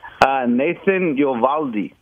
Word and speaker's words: Nathan Eovaldi: Nathan Eovaldi. – Okay. – That's always great when you don't know how to say it. – I think I said it right. – Yeah, I think you did.